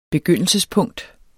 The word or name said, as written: begyndelsespunkt